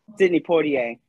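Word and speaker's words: Sidney Poitier: Let's just give our condolences to Sydney, the actor, Sydney Poitier. Mm-hmm. Did I say his name right? Please. – Yep. Yeah.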